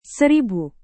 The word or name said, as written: seribu